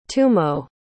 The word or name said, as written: tumo